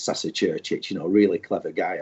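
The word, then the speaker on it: Saša Ćurčić: So they said, we're asking, how would you pronounce Sasa Chirich? So I said, no, it's Churchich.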